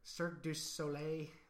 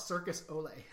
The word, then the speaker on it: Cirque du Soleil: I have never been able to pronounce that right. And I know some people call it Circus Olay. – That's what I call it, Circus Olay. That's not right.